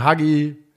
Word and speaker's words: Hagi: Also man muss sagen, Hagi, spricht man das Hagi oder Haji? – Haji. Ja, so wie sein Vater.